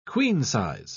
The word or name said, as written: queen-size